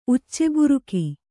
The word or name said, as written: ಉಚ್ಚೆಬುರುಕ